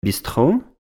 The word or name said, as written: Bistrot